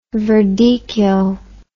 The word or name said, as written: verdicchio